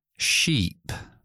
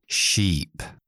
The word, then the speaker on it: sheep: Y una comparación clásica, de hecho hay un libro que se llama así, de pronunciación, es la diferencia entre ship, barco, y sheep, oveja. ¿Notáis la diferencia? ship, ship, la i, i, i, i, ship, ship, sheep, oveja, sheep, i, está la i metido.